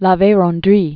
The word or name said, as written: La Verendrye